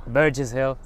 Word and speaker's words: Burgess Hill: Jsme v Burgess Hill, možná to vyslovuju špatně. – Jak Burgess to... Hill. – Burgess Hill, přesně tak.